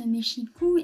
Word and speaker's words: Mexico: donc on prononce Mexico et pas euh, Mexico ou Mexico, comme, on pourrait le, penser à la lecture.